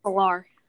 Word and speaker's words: Pillar: Pillar. He, he's a former San Francisco it's, Giant. It's Pilar. – Oh, P- pronouncing his name mm-hmm. wrong. Sorry, Pilar.